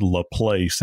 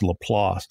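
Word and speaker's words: LaPlace: And people that are not from South Louisiana, many times they'll call it La Place, and that's the way it's spelled, but it's La Place.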